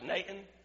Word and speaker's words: Nain: Now, that should be pronounced Nain. It's in two syllables, but everybody says Nain, so that's what we're going to say too.